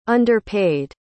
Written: underpaid

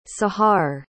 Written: sahar

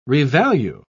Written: revalue

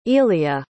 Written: elia

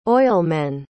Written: oilmen

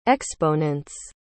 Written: exponents

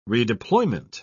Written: redeployment

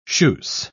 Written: schuss

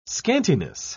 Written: scantiness

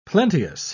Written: plenteous

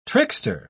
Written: trickster

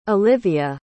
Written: Olivia

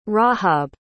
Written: rahab

